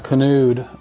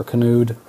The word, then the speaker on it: Kanude: But our last name in Northern Europe is always pronounced as the name Knut or Kanude, with the K and the N both being pronounced.